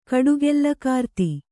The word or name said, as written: ಕಡುಗೆಲ್ಲಕಾರ್ತಿ